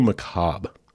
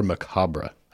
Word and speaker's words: macabre: I had to look into that sometime back because I wasn't sure how to pronounce it. I'd always heard it macabre. Apparently both ways are correct, just for your information.